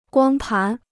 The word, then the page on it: 光盘: guāng pán